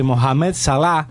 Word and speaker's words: Mohamed Salah: y una supuesta falta sobre Mohamed Salah, que ¿cómo se ve bien que lo pronuncias vos? – Mohamed Salah.